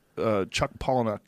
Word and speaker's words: Palahniuk: I mean, I was talking about this with Chuck Palahniuk yesterday. Did I say his name right? Yeah. Yeah.